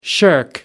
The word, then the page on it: shirk: American English